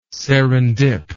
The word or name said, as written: serendip